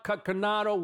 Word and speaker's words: Coconato: I want you to help me welcome my special guest, a national speaker, evangelist, pastor, a voice for this generation, Pastor Todd Coconato.